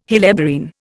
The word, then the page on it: helleborine: Indian